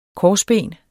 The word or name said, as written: korsben